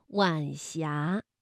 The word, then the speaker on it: 晚霞: 晚霞是晚和阳平字相连，声调呢读二一调，晚霞。